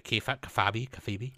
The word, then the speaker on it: Kayfabe: Cartoonist, one word, and then the second word is Kayfabe, K-A-Y-F-A-B-E. Yeah, like yeah. – And I don't even know if I'm saying that right. Kayfabe? – I think it is Kayfabe.